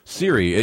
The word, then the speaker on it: Siri: You talked about a voice recognition software product, and I have it phonetically written down as Siri or Shiri. – Siri, yeah, S-I-R-I.